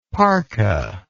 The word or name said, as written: parca